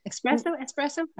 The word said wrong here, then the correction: espresso: I said it wrong. – Espresso with an S. – Espresso.